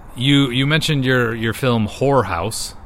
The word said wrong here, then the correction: Horror House: Horror House. – H-O-R-R. – Several syllables.